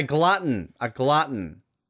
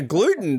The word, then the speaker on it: glutton: I was about to say a gluten. That's a new one, but a glutton. Okay. That works.